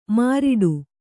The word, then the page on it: ಮಾರಡಿ: māraḍi